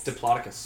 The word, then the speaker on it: Diplodocus: Diplodocus. – Yeah, like Diplo.